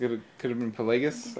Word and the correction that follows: Plagueis: Darth Plagueis. – Pelagus. – Did I not say this? Did I say it wrong? – Yeah, you said it weird.